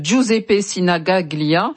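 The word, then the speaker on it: Giuseppe Sinigaglia: Si vous n'avez regardez sur les réseaux sociaux, c'est le stade Giuseppe Sinagaglia, j'espère que j'ai bien prononcé, Matteo.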